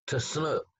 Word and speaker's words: Chinook: And I might say the word Chinook, that's the way we white people pronounce their tribal name. We pronounce it in all of our books, you'll find will be C-H-I-N-O-O-K, Chinook. But they don't say it that way. They put it like a T-S sound to start with. T-snook, t-snook, t-snook. That's the way they pronounce it.